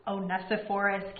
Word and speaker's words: Onesiphorus: Oh, Onesiphorus.